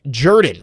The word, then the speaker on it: Jordan: Okay, so Josh says it is pronounced Jordan, right next to Lake Jordan. We're getting some Auburn alums who are saying that is correct. It is Jordan, not Jordan.